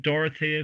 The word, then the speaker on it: Dorothea: Is your wife the woman Dorothea, if that's how I pronounce it? – Correct. – And what's her second name? – Hurley. Dorothea Rose Hurley.